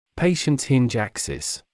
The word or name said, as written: patient’s hinge axis